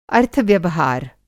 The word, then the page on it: अर्थव्यवहार: अर्थ-व्यवहार